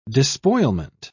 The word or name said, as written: despoilment